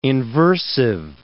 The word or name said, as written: inversive